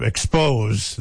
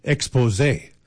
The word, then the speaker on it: Exposé: I love the little accent on the second E, so instead of calling them the group Expose, they're Exposé.